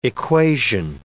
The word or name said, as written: equation